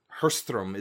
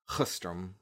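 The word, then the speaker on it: Sjöström: But Hirschström is apparently how you pronounce it. It is S-J-O with an umlaut, S-T-R-O with an umlaut M. So it starts with an S-J and it's pronounced Hirschström. Hirschström.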